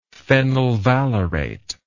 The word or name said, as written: phenyl valerate